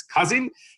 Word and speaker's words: Cousin: The family name is Cousin.